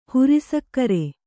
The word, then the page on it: ಹುರಿಸಕ್ಕರೆ: kannada